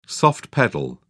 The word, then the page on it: soft-pedal: British English